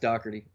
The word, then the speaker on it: Doherty: And it's actually pronounced Doherty, by the way.